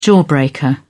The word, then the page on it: jawbreaker: British English